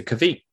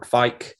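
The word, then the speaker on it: Kveik: And the key kind of thing about this one is that it's a Kvik or a Kvike, depending on how you say it, IPA.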